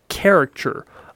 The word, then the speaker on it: caricature: You are a caricature of what you think. – Caricature. – Yes. – You gotta add an extra syllable in there.